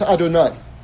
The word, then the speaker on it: Adonai: It would be pronounced Adonai, by the way.